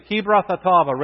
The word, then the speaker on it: Kibroth Hattaavah: Would you say that with me? Kibroth Hattaavah. Ready? Kibroth Hattaavah. Now say it like, you know, like a Middle Easterner.